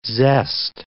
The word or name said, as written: zest